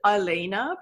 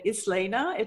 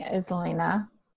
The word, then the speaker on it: Islena: Her name is spelled Islena. And I keep wanting to pronounce it Ilena because that just sounds better when I put it in my mouth than Islena. It just... – Right. – How do you say it in your head? – I was saying Islena. – Okay, Islena.